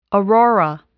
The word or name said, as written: aurora